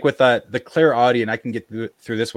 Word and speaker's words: clairaudient: And the second, I suppose, would be clairaudient, if I'm pronouncing that right. – That's right.